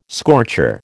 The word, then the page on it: scorcher: American English